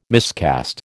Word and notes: miscast: American English

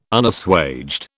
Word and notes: Unassuaged: British English

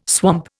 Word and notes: swamp: Indian

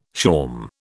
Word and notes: shawm: British English

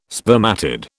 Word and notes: spermatid: British English

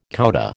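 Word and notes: coudé: American English

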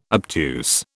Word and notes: obtuse: British English

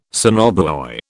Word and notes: sonobuoy: British English

